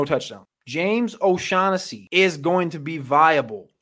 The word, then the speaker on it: O'Shaughnessy: James O'Shag Hennessy. Yes, I know it's pronounced O'Shaughnessy. It's James O'Shag Hennessy for me.